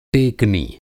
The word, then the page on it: टेकनी: Hindi